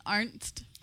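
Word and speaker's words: Arzt: You want to do Hurley and I'll do Arndt. – Okay. – All right. Go ahead. – Let me ask you something, Arndt. – It's Arndt. – Arndt. – Arndt. No, not Arndt. Arndt. A-R-Z-T. A-R-Z-T. Arndt.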